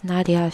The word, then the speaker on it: Nadia: Hello, my name is Nadia She. It's spelled N-A-D-I-A.